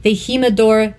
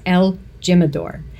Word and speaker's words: El Jimador: So number 6, the Jimador Tequila New Mix. Canadians may pronounce it as El Jimador. You might know that better, but it's the Jimador.